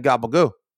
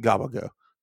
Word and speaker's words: capicola: And now all the time I'm like, yeah, I ain't gobble goo. All the time. That's capicola, by the way. The word is capicola. It's pronounced gobble goo. Gobble goo.